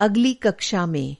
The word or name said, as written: अगला